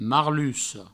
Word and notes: marluca: marlusso

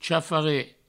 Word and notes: chafaret: provençal

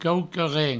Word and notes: quauqua-ren: provençal; quaucaren

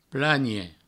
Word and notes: planhe: plagne

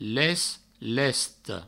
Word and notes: lest, lesta: provençal; lest, lesto

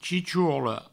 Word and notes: chichorla: chichourlo; provençal

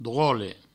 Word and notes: drole: Provençal

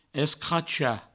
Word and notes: escrachar: Provençal; escracha

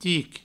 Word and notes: tic: ti

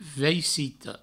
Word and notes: vaicito: veicitou

